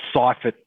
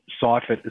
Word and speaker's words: Seiffert: Or Seifert, Seifert, as they would call him. – S-E-I-F-F-E-R-T, yeah.